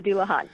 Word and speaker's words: Dullahan: And the episode that just came out this week is about the headless horseman myths, the Dullahan. Am I pronouncing that right? – Yes, the Dullahan. Yes.